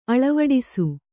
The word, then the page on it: ಅಳವಡಿಸು: aḷavaḍisu